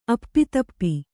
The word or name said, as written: ಅಪ್ಪಿತಪ್ಪಿ